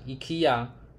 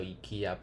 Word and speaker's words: IKEA: 那像 IKEA，它本来就应该叫 IKEA，不是 IKEA 哦啊。